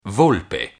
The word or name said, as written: volpe